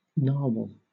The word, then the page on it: narwhal: British English